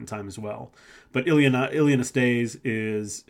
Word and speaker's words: Ilie Năstase: It's, I believe I'm pronouncing this correctly, Ilie Năstase, that's how you pronounce it.